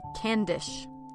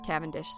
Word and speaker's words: Cavendish: 400 years ago, we might have pronounced her name Candish instead of Cavendish. – Ah. – But nowadays everybody says Cavendish, so that's what we're going to go with.